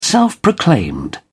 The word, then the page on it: self-proclaimed: British English